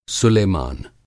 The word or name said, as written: सुलेमान